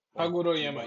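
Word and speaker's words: Haguroyama: Hagoroyama. – Hagoroyama.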